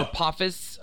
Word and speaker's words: Apophis: Yeah, Apophysis. Yeah, it's like Apophysis 90999 or some shit. Yeah. – No. – Apophis. I forgot how you say it.